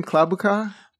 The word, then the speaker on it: Klobuchar: Klobuchar.